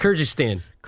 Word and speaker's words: Kyrgyzstan: Kyrgyzstan.